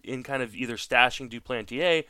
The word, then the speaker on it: Duplantier: First of all, let me commend you on the best pronunciation of Jean Duplantier yet so far that I've heard. So, why, thank you. French was my first language.